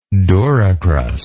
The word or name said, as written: duricrust